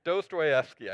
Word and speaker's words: Dostoevsky: Fyodor Dostoevsky. Did I say that right? Anyone? Dostoevsky? Okay, Dostoevsky. I said it just right the first time.